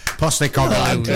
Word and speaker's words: Postecoglou: How do you pronounce it? Postecoglou or whatever? – You've got it. – Big Ange is easy. Postecoglou.